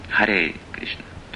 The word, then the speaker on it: Харе Кришна: В Индии, как Шрила Прабхупада иногда повторял, Харе Кришна, там говорил, с таким оттенком. Пишется Харе Кришна. Можно так и повторять. Харе Кришна.